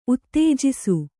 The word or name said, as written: ಉತ್ತೇಜಿಸು